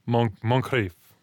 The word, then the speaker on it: Moncrief: Moncrief. Is that a good pronunciation? – Think more Scottish. If you can roll your eyes like a Scotsman, then you got it. – Moncrief.